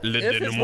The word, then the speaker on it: denouement: Le denouement.